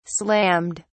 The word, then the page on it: slammed: British English